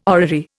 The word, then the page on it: orrery: Indian